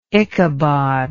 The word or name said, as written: ichabod